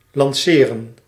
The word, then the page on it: lanceren: lɑnˈse.ɾə(n); /lɑn.se.ɾǝⁿ/